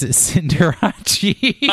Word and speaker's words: Cinderace: But in my brain, I think Liberace. And so I pronounce it as Cinderace.